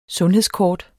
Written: sundhedskort

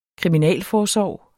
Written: kriminalforsorg

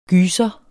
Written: gyser

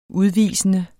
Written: udvisende

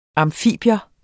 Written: amfibium

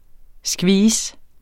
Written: skvis

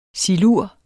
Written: silur

